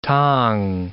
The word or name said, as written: T'ang